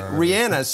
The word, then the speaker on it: Rihanna: I'm not going to deliver a history lesson, but a pronunciation lesson, because I've been getting a lot of you're mispronouncing Rihanna. That is how she pronounces it. And if you go on to YouTube, you can actually do the research, multiple interviews. Rihanna. So I'm going to trust the source myself as Rihanna.